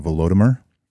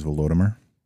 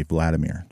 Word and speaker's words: Volodymyr: Between 980 and 1015, it was ruled by a grand prince by the name of Volodymyr, and that's the correct pronunciation, is Volodymyr. Based on the language of the time, if you were to translate that into Russian, it'd be Vladimir, just like Vladimir Putin, or in Ukrainian, it'd be Volodymyr, just like Volodymyr Zelensky.